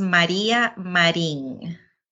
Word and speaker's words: Maria Marin: It is pronounced Maria Marin.